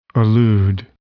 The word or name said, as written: allude